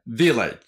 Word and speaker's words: village: Village。 Village。この Village の V は V なんで、軽く唇を上の歯に当てて、 V、 V、 V。唇と口を合わせて、 Village、 V、V、 Village じゃなくて、 Village。 Village と発音します。